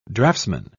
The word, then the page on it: draftsmen: British English